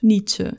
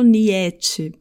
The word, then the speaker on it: Nietzsche: Eu sempre faço então a conexão com o nome de filósofos, Nietzsche. Eu não falo Nietzsche, porque nesse par de vogais juntas, ou seja, nesse ditongo, somente o I é pronunciado. A palavra nunca, ni, se escreve com N-I-E, mas é pronunciada somente como se fosse um N-I, ni.